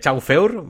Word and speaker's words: Chauffeur: ah, bueno, es es, es, que es francés, es francés. Eh, Chauffeur, ¿vale?